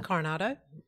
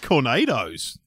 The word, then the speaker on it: Cornados: Say yes to the Coronado. Or maybe it's – so I'm probably pronouncing it wrong. Maybe it's Coronados, like nachos. – Or maybe it's just Cornados, like tornado.